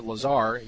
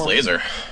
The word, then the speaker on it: Lazor: Moving over to the Miami Dolphins, they have a new offensive coordinator, Bill Lazor, or Lazar. You probably know how to say it better than I do. – I believe it's Lazor.